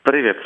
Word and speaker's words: Privet: Privet, l'ho detto bene? – Privet, sì, benissimo.